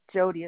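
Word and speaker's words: Jodee: Okay, my first name is Jody. It's spelled capital J O, capital D E E. Last name is Tracy, T-R-A-C-Y.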